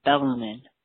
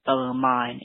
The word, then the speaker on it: Bellarmine: I just want to correct the entire lacrosse community on the pronunciation of Bellarmine. It is not Bellarmine, Bellarmine. It is Bellarmine. You don't pronounce the E at the end.